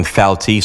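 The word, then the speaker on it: Phalti: Now, I heard the pronouncing Phelti, so that's the pronunciation we'll use today.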